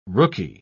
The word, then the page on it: rooky: British English